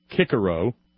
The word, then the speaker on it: Cicero: Or correctly pronounced in his mother tongue, Cicero.